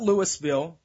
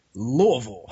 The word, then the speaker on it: Louisville: Not Louisville, not Louisville, Louisville. A lot of people don't get that right.